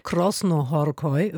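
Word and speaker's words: Krasznahorkai: Krosnohorkoi war letztes Jahr die richtige Aussprache.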